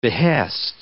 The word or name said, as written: behest